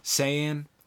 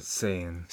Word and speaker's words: Saiyan: Did I pronounce that right? Saiyan? How do you pronounce it? – Saiyan. Is typically how it's said.